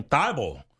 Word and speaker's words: Thybulle: I was going to ask you how to pronounce his last name. Thybulle. – Yes. – T-H-Y-B-U-L-L-E.